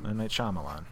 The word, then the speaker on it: Shyamalan: M. Night Shyamalan. Is that the correct pronunciation? – I think so. That's what I've always heard. – Yeah.